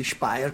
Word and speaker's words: Speyer: Sì, è una foto che ho scattato io al museo di Speyer, come dice, come ricorda correttamente la pronuncia Mike,